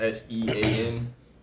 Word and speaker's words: Sean: It's like the S, the S E A N. – It's Sean. Like, it's Sean, and I don't get it.